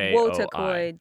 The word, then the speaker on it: Wotakoi: Watakoi. – Oh, Watakoi. – How do you spell that? – W A T A C I think it's Wotakoi.